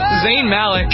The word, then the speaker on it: Zayn Malik: Zayn Malik.